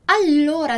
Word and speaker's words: allora: Allora, lembra que a gente já falou sobre isso, que a gente vai começar muitas frases no italiano com allora, allora, com le due L, allora. Então, atenção aí na pronúncia dos dois L's.